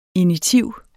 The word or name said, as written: initiv